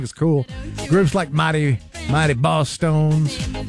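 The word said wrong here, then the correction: Bosstones: I think I mispronounced it. Boss Tones, yeah.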